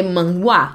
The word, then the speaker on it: manhwa: Sim, porque o manhua, ele se escreve M A N H-W-A. Você lê manhua. Uhum. E o manhua chinês é M-A-H-U-A, né?